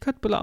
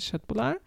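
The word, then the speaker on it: köttbullar: Das wird nicht Köttbülar ausgesprochen, sondern Schöttbülar.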